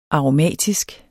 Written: aromatisk

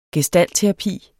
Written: gestaltterapi

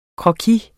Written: croquis

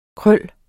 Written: krøl

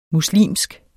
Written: muslimsk

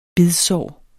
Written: bidsår

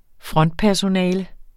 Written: frontpersonale

